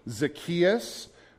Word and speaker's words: Zacchaeus: Now, I was working with Kimiko earlier, and she corrected me, and she said the proper pronunciation was Zacchaeus. All right, if you were raised in Sunday school and you've been around church before, you may say Zacchaeus, you may say Zacchaeus.